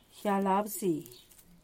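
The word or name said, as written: gllar abzin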